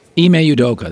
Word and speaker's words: Ime Udoka: Ime Udoka, that's better. I butchered that thing about once a week.